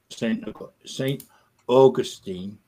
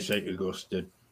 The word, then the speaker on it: Saint Augustine: I think a couple of people in the chat mentioned when we mentioned it before, but you get to a place called Saint Augustine, which is the American pronunciation. We say Saint Augustine, but hey, let's keep it with Saint Augustine because it's really nice.